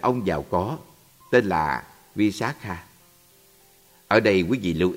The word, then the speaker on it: Visakha: Nếu như người ta là một phụ nữ, tên họ sẽ được phát âm là Visakha với âm Á dài. Còn nếu là người nam, tên họ được phát âm là Visakha với âm A ngắn.